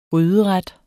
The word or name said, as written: gryderet